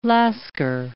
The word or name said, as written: lascar